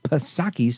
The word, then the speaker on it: Psaki: All right, that is America's climate czar, John Kerry, on with former White House press secretary and now MSNBC host Jen Psaki, silent P.